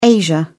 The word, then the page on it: asia: British English